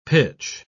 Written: pitch

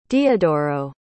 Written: diodoro

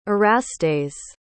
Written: erastes

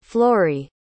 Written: florey

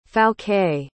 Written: Fouquet

